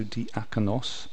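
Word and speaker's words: diakonos (διάκονος): So the Greek here is diakonos. So I looked in my Greek and the emphasis is on the a, so diakonos.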